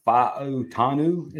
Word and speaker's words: Faotanu: I think it's Faotanu is how you say it. There's like some enunciation.